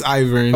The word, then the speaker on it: Ivern: You ever, it's Ivern.